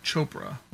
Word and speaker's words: Chopra: Or Chopra, like Deepak. – Like Deepak, and.